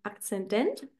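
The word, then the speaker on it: Aszendent: deinem ähm, Sternzeichen und deinem Akzendent. Habe ich es richtig ausgesprochen? Ja. Genau. Und dann gibt es ja noch diesen ähm, Chinki.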